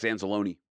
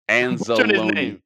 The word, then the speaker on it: Anzalone: But, you know, there was one play where he kind of got slammed by the linebacker, Alex Anzalone. – Azoni. However you say his name. – Anzalone. – I'm butchering his name.